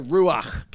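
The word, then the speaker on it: ruach (רוח): The Hebrew word for spirit is a word ruah. But when you say it, you got to say it like a Hebrew. So you got to do that at the end, right? So go ruach.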